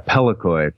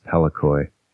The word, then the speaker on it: Pellikoi: Yeah, Pelikoi. P-E-L-L-I-K-O-I is Pelikoi. That's a proper name.